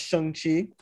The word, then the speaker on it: Shang-Chi: I was trying not to say Shang-Chi because that's like how we normally pronounce that, but I'm definitely guilty of that. – Yeah, it's pronounced Shang-Chi, so trying to make sure I get that correct.